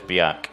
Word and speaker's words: Björk: I saw Björk there, who the Icelandic singer, who people mispronounce their name, but it's Björk.